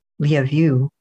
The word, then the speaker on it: Lviv: I think in Ukraine, which is where it is now, it's Lviv, which sounds like love you. And supposedly the American pronunciation is Lviv, which is hilariously not what it should be.